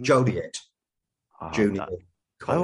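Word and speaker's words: Joliet Junior College: I will pronounce the name just so we can get it right. Mm-hmm. Joliette Junior College. – I wasn't even close, you know that? – Because no, you weren't close.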